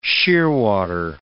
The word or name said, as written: shearwater